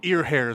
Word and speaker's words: Earnhardt: Is it E-A-R-N? – Ear hair is how you say it.